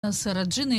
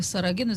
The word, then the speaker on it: Сарагина: Но тем не менее, названа эта румба именно Сараджина или Сарагина, в зависимости от языка, на котором это произносится.